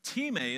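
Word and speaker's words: time (τιμή): It looks like time in English. I know that. But time is how you say it.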